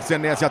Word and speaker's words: Senesi: A gente vai de Marco Senesi ou Senesi, a pronúncia correta? – Senesi, Senesi. Senesi, então o narrador estava falando certinho, né? – E o Anderson também.